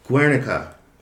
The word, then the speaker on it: Guernica: Guernica. Or how would that be pronounced more correctly? – That sounds about right.